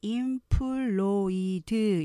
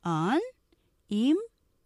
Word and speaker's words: unemployed: unemployed 마찬가지로 unemployed 이렇게 한국식의 스타카토 음절로 발음하시면 안 되고요. 정확한 이 단어의 음절은 unemployed employed 하면서 뒤에 액센트 있어요. 연결하면 unemployed 이렇게 발음할 수 있죠.